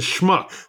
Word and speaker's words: schmuck: Because, I mean, every single one of us at some point in our lives feels like a smuck. – A schmuck. It's a schmuck. – Smuck. – It's pronounced schmuck.